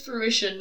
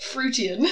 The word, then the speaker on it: fruition: It's fruition, not fruitian. – Fruitation. – Fruition.